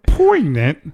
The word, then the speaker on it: poignant: I like the word poignant. It's hard to say. – It sounds like pregnant. It sounds like somebody that can't say their R's pronounce it pregnant. – Poignant. – It's poignant.